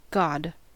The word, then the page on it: god: American English